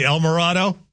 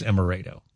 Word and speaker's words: Emerado: He would say El Morado. It's Emerado.